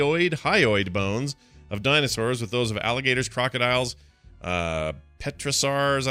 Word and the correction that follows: pterosaurs: am I saying that right? Pterosaurs and modern birds. – Sorry, I was looking. Pterosaurs. – The P is not in there.